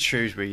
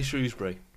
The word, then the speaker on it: Shrewsbury: That's how you say it. It's Shrewsbury, not Shrewsbury.